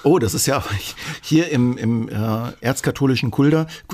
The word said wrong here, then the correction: Fulda: äh, Fulda, ähm, äh, ich kann es nicht mehr, mehr richtig aussprechen.